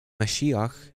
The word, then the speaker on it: Mashiach: Yeshua, Mashiach, would be in the Hebrew pronunciation.